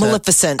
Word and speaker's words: Maleficent: Maleficent.